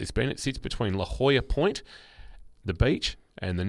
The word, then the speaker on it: La Jolla: So it looks like La Jolla, J-O-L-L-A, but right. It's pronounced obviously in a Spanish.